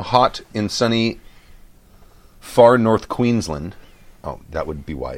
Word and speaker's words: Queensland: Pronunciation guide for anyone taking a punt at the Aussie accent, Queensland. – Queensland. You did a good job with that.